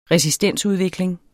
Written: resistensudvikling

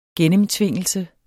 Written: gennemtvingelse

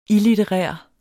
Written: illitterær

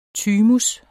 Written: thymus

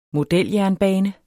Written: modeljernbane

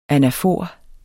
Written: ANAFOREN

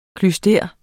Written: klyster

